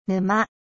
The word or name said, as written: marais